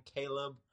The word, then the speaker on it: Caleb: So you can just go to Instagram and you can type my name in, Caleb, with a C, and Stillians is spelled STILLIANS.